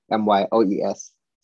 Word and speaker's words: Myles: So again, milestone, as I spell it for milestone for the Insta, MYLES Tone underscore coach. I'm on LinkedIn as well. If you want to see me on LinkedIn, that's with my name, Myles, M-Y-L-E-S, Sadie Nesbeth, A-S-I-E-D-U hyphen N-E-S-B-E-T-H.